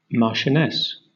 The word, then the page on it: marchioness: British English